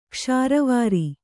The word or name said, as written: ಕಿಸುರ್ವೇರ್